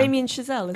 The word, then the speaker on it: Damien Chazelle: Damien Chazelle.